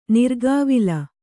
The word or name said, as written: ನಿರ್ಗಾವಿಲ